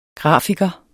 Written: grafiker

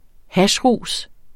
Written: hashrus

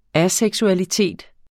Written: aseksualitet

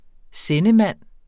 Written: sendemand

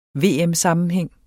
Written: VM-sammenhæng

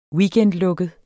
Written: weekendlukket

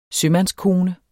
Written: sømandskone

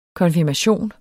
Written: konfirmation

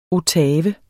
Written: ottave

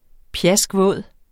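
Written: PJASKVÅDE

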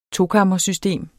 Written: tokammersystem

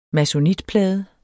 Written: masonitplade

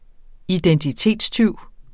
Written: identitetstyv

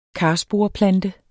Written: karsporeplante

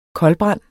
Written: koldbrand

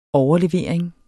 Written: overlevering

